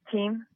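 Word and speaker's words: Thiem: Whoever gets out of that, again, the top seeds, Murray, Nishikori, would take on potentially either Raonic or how do you pronounce Dominic's last name? Them? – Team. – Team. – Yeah.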